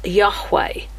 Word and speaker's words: Yahweh: And God reveals himself here as the Lord God, and that's capital letters L-O-R-D, which is the Hebrew tetragrammaton, Yahweh, Y-H-W-H. Orthodox Jews regard this name even today as too sacred to be pronounced. We say it as Yahweh, which we have actually transliterated Jehovah, or someone did, I don't know his name, by putting vowel sounds in between the consonants.